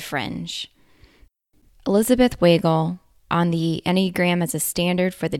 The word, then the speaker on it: Enneagram: Yes, it's a funny word, pronounced Enneagram, but it's not at all a strange system.